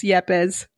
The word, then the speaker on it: Yepes: That's why the Latina's here to tell you. That's actually one of my last names, Yepes.